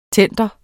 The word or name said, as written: tender